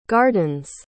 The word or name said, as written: garden's